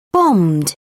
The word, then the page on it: bombed: British English